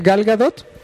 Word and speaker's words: Gal Gadot: Gal Gadot. Así tal como suena. Gal Gadot. Gadot. G-A-D-O-T.